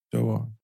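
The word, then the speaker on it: Duaa: Three Muslim girls, Aisha, Muna, and I think it's Dua. I don't want to say her name incorrectly. – Probably Dua. – Dua. It's D-U-A-A.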